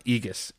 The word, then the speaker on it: Aegis: But it's pronounced Aegis. It's A-E-G-I-S.